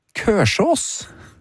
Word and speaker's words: Kolsås: Der står det navnet Kolsås, uttales tradisjonelt Kørsås. På lokal bærumsdialekt.